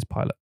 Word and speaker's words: Pilate: I'm choosing Trial Before Pilate, or Pilate, depending on how you choose to pronounce it. It is Pilate, but a lot of people go Pilate. It's not Pilate.